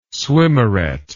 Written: swimmeret

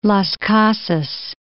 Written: Las Casas

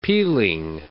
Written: peeling

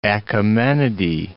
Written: Achaemenidae

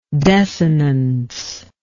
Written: desinential

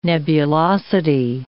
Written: nebulosity

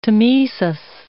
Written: tmesis

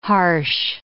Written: harsh

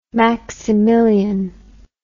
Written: maximilien

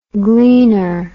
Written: gleaner

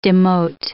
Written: demote

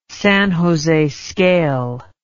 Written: San Jose scale

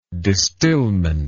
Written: distillment

